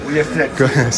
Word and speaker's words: Ullastret: Pues estamos aquí en Ullastret, ¿lo he dicho bien? – Sí, Ullastret.